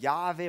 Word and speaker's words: Jahwe: Manche sprechen es als Jahwe, manche als Jehova aus. Man weiß halt nicht genau, wie man es aussprechen soll, weil der Name ist so heilig, dass man ihn nicht aussprechen durfte.